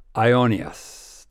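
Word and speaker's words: aionios (αἰώνιος): The Greek word that we usually translate as eternal in these passages is the word aionios. It's a Greek word. It's spelled, just in case you want to Google it, it's spelled A-I-O-N-I-O-S, aionios.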